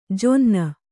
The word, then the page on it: ಜೊನ್ನ: jonna